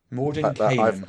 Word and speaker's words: Mordenkainen: The wizard Mordenkainen. Gygax says the character is patterned after a Finnish wizard. So it's actually Mordenkainen. So I've always said Kainen on that. It's Kainen. Mordenkainen.